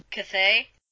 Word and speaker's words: Cthaeh: Cthaeh. – Cthaeh.